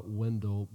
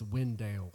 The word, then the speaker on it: Wendell: But it's like people call it Wendell, but it's not pronounced Wendell. – It's a Wendell. – It's Wendale.